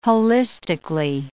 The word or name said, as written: holistically